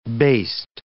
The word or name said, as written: based